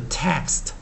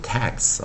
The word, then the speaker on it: text: 注意啊，这个 text 不要念成 tax 哦，tax 是啊 t a x 哦。